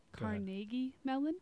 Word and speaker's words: Carnegie Mellon: I just want to correct Carnegie Mellon. – Thank you. Yeah, that's the right pronunciation.